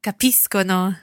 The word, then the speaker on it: capiscono: Capiscono. – Capiscono. And of course, it's being followed by an O there. So capiscono, it's a hard C sound.